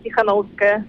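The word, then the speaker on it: Tsikhanouskaya: Come si pronuncia questo cognome, che per noi è davvero impronunciabile? – Svetlana Ivana Tichanowska.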